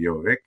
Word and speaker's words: Govig: Pronounce it with the Norwegian, then it's more with the wine sound, Jovig.